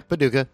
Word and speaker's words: Paducah: Paducah.